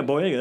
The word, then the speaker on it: Boyega: Yeah, Boyega.